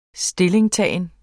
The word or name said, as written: stillingtagen